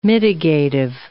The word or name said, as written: mitigative